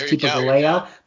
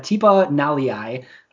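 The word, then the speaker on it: Tipa Galeai: Two edge rushers, Jonathan Garvin and Tipa Naliai, which I always thought it was Tipa Galea, but Tipa Naliai. is apparently how you pronounce it, at least according to the Packers media guide.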